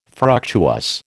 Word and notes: fructuous: American English

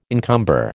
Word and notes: encumber: American English

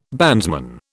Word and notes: bandsman: British English

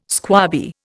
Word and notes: squabby: Indian

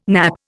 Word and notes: nappe: Indian

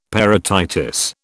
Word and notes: parotitis: British English

